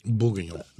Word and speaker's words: Bourguignon: Bourguignon.